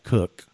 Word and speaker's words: Coke: Um, reaching back to John's lessons with uh, some of the famous English jurists like Cook it's spelled Coke, but it's pronounced Cook.